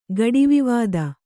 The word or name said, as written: ಗಡಿವಿವಾದ